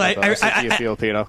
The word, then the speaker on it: DeFilippo: You know, so DeFilippo. I'm adding syllables to his name. Yeah, I don't know why I called him Filipino. He's definitely not Filipino.